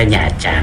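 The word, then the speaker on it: Nha Trang: หยาจาง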